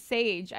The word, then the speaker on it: Sage: First of all, I've never known any other Sages, but if a dude name was Sage, I'd be like, that's actually kind of cool.